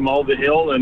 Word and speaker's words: Mulvihill: Well, we went to, I can spell it for you, M-U-L-V-I-H-I-L-L.